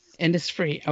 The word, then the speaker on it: Innisfree: is that how it said? – Innisfree, okay. – It's there's an H in there, so you pronounce the whole thing Innisfree.